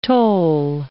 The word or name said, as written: tole